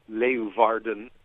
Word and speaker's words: Leeuwarden: First of all, it's pronounced Leeuwarden, that city up in the north of...